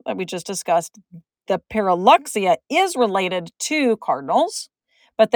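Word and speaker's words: Pyrrhuloxia: It is pronounced Pyrruhuloxia. So you do actually pronounce the X. I have heard some people pronounce it Pyrruhulia.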